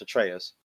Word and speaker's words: Atreus: So Atreus, correct? – Yes, Atreus.